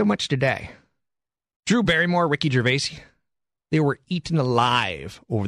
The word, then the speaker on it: Gervais: I was just corrected. His pronunciation of his name is Gervais.